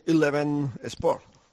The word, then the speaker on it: Eleven Sport: Muy bien, por cierto, ha pronunciado muy bien Eleven Sport.